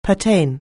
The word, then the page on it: pertain: anglais